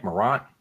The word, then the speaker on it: Morant: How do you say his last name? Is it Morant? – Morant.